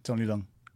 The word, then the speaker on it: Leung: is that how you say his name? – Yeah, Tony Leung.